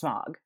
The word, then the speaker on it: Smaug: All of us were so upset about Smaug. – Yes. – Smaug. Nobody would ever pronounce it that way. It's Smaug.